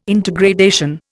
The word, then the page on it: intergradation: Indian